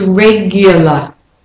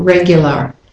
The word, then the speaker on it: regular: But the main thing is the y in the middle there, after the g. So it's not regular, which is how it's pronounced. How it's written, how it's spelled. But regular or regular. Yeah? But the g and the y. Regular. So it's as if it's Y-O-U.